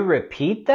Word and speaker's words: repeat: Vamos a subir la voz y entonación en la segunda sílaba de repeat. Repeat. Tenemos el sonido vocálico largo también en repeat. E, E, repeat.